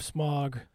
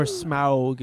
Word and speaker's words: Smaug: First off, probably the biggest release, The Hobbit, The Desolation of Smaug. – Woo-hoo. Or Smaug, if you want to get all hoity-toity about it.